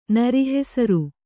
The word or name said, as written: ನರಿಹೆಸರು